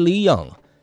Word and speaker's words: Linion: 好，接下来这首新歌很适合小周末来收听，它叫做这名字有点难发音哦，叫做 Lino 吗？哦，Linion，大家可以自己上网搜寻一下。官方发音好像是 Leon，但是粉丝也叫他黎农哈，Lino 应该是怎么样发音都可以吧。